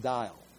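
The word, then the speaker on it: Dajjal: This is now pronounced, it's D-A-J-J-A-L. It's pronounced, from what I could get online as I hit some pronouncement punctuation keys, it's pronounced dial.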